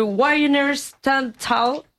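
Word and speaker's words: Wiener Stadthalle: Alors attention pour la prononciation. Le Wiener Stadthal.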